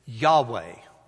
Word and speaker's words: Yahweh: That word for God in the Hebrew language, we pronounce it Yahweh, Y-A-H-W-E-H. In some translations of Scripture, it will have a four-letter tetragrammaton, it's called, Y-H-W-H.